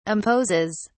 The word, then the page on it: imposes: British English